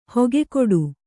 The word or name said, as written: ಹಕ್ಕುಗಟ್ಟು